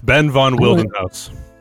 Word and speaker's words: Ben von Wildenhaus: It's Ben von Wildenhaus.